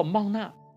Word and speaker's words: 玛拿: 经上记着，以色列人看见了，就彼此对问说，这是玛拿什么？原来他们不知道那是什么。玛拿在希伯来文中读作玛纳，翻译成希腊文就是玛纳，再翻译成中文就成了玛拿。